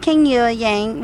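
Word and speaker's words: Kang Youa Yang: Hi, my name is Kang Yua Yang. Well, it's spelled K-A-N-G-Y-O-U-A, Yang, Y-A-N-G.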